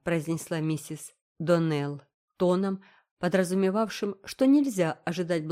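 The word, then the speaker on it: Donnell: «Клариса Эльмира сказала мне, что вы произносите нашу фамилию – Доналл. Так вот, мисс Ширли, правильное произношение нашей фамилии – Доннелл. Ударение на последнем слоге.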